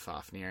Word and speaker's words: Fafnir: Fafnir, I may be pronouncing it wrong. – No, tweet at me. – No, you're right.